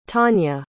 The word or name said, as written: tanya